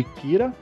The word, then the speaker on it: Kira: A corrida do L atrás do é, Light Yagami, carinhosamente apelidado de Kira, que, se, que é como se fosse a pronúncia japonesa da palavra killer, que significa assassino, né?